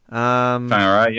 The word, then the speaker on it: Whangarei: Number three, and I'm going to say this properly, is it Whangarei? – Whangarei, yep. – Whangarei, yep. See, I remember the WH.